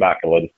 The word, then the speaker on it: McLean: Closer, but still no cigar. Duncan McLean. It's a proper Northern Irish pronunciation.